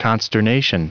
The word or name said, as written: consternation